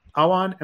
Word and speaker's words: Awan: But first of all, I'd like to introduce Haseeb uh, Awan.